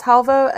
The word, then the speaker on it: halwa: I'm very, very down for any sort of, I think it's called halwa, but might be pronounced halva. It's an Indian dessert. That's a carrot based dessert. That's really yummy. Let me make sure I'm halwa, carrot. Oh, maybe it's called halwa puri, or maybe I'm too white to pronounce this. No, it's called carrot halwa, H-A-L-W-A.